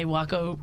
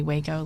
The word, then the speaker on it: Waco: Well, as we say, Waco rhymes with taco because we get people who obviously say Waco, like Texas.